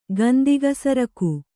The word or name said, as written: ಗಂದಿಗಸರಕು